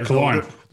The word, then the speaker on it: Killorn: Killorn, how do you say it, right? I'm going to get taking some heat. – Yeah, Killorn. – There's Killorn. No H in it. Killorn.